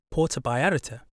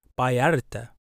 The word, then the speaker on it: Vallarta: When I first spoke to Paola, I said Puerto Vallarta because that's how it's spelt, with a V. But she told me how to pronounce the word properly, which is Vallarta.